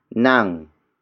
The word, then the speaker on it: nang: Ako is spelled as A-K-O. It means I, the focus of this sentence. The next word is nang. Nang. Nang. Nang is spelled as N-A-N-G.